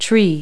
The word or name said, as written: tree